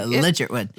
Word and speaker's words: Ligertwood: Am I pronouncing it? – Ligertwood. – Ligertwood. Okay.